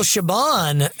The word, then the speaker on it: Chabon: Michael Chabon had written one of the earlier, or I never know how to pronounce his name. He's one of my favorite authors, but he wrote. – That's how you pronounce it.